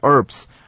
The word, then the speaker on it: herb: C 段我们首先仍然是看 Robert 所说的第一段话，他说 How do I use these herbs? Herb 这个字呢 H-E-R-B, 注意到它的 H 是不发音的啊，所以念作 herb, 千万不要念 herb, 这个呢就很怂了啊，念作 herb。